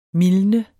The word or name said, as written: mildne